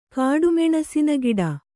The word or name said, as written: ಕಾಡುಮೆಣಸಿನಗಿಡ